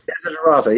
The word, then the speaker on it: Desiderata: I do remember that we had that, I can't remember how to pronounce it. Is it called Desiderata? – Desiderata poem? – Desiderata, yeah.